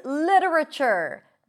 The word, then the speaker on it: literature: You have two ways to pronounce this word. Li-ra, with a flap T. Ra, another reduced syllable. And chur, with a ch sound. Literature. Literature. But you can also say lit-ra-ture. So here we don't have the flap T because we drop one syllable. Literature. So either literature or literature.